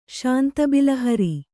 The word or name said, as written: ಶಾಂತಬಿಲಹರಿ